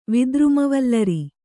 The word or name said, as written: ವಿದ್ರುಮವಲ್ಲರಿ